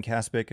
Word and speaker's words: Kaspick: You can follow me there at Ben Kaspic. If you're on YouTube, this is the Twitter handle right there. Last name is spelled K-A-S-P-I-C-K.